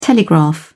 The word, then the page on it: telegraph: British English